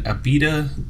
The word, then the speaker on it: Abita: Me and Aaron are drinking Abita. Am I saying that? – Abita's correct.